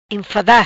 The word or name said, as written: Enfada